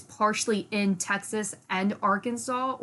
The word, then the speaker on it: Arkansas: Still don't like that it's pronounced Arkansas.